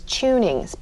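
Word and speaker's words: Chowning's: There's a handful of them, and one of them, which we will be pronouncing today as Chunings, but if you see it in the historic area, it will look like it's spelled Chownings.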